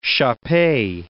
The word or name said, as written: shar-pei